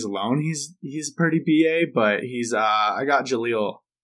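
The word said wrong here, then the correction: Jellal: I want to say that's how you say it from Fairy Tale. – Jalal. – There you go. So yeah, Jalal.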